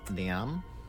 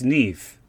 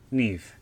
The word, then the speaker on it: Niamh: I wasn't sure how to pronounce her name. I'm just going to go with Niam. Niam. – Oh, it's Neve. Neve. – Neve? Like with a V for Victor? – Yeah. – Ah, so it's spelled N for Nelly, I for Indigo, A for Apple, M for Mary and H for Harry. – Maybe it's Neve.